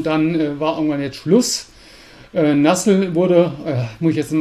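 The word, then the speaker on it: Nuzzel: Anlass war, der wirkliche Anlass war die Einstellung von Nassel oder Nützel oder ich glaube, Nassel ist die korrekte Aussprache im Englischen, ja.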